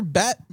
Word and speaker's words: Bette: It's spelled, I mean, it's pronounced differently, obviously, but it's spelled the same way as Betty Davis, right? – Then why isn't it Betty Midler?